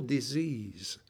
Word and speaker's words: dis-ease: Le mot se prononce alors « dis-ease ».